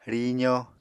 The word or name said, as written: rizo